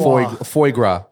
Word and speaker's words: foie gras: It's foie gras.